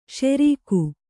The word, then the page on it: ಷೆರೀಕು: ṣerīku